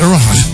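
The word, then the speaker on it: Erewhon: So she's collaborating with a company that I can't even pronounce. It's Erwahan. – Yes, it's Erwahan.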